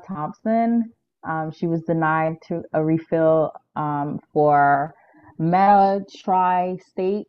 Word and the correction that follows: methotrexate: Oh yeah, methotrexate.